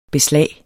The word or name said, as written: beslag